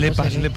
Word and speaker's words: Lepas: Grupo Changán y Lepas, que lo digo mal. – Lepas, el Alimotor.